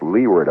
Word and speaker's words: Leeward: That's L-E-E-W-A-R-D. More properly, I suppose, pronounced Leeward Islands, but it's always pronounced Leeward in the old salty sea fashion.